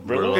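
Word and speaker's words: Brilleaux: It's not spelt like a Brillo pad. It's like, it's spelt like it was French. It's the French Brillo pad. That's what it's spelled like. – B-R-I-L-L-E-A-U? – E-A-U-X.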